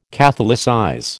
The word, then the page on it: catholicize: American English